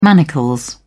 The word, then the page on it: manacles: British English